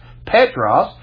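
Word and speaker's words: Petros (Πέτρος): In the Greek, the name Peter is pronounced Petros, which means a piece of a rock that you can hold.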